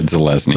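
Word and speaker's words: Zelazny: Dick, Roger Zelinsky, Roger Zelezny, right? That's how you pronounce it. – I think it's Zelezny. I've always said Zelezny. Zelezny. I who knows? – Yeah, Zelezny. John knows.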